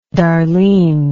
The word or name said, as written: darlene